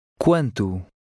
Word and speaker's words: Quanto: Number 11 has a Q-U-A combination. This is pronounced the same as in English. Try to read number 11. – Quanto? – Now read number 12.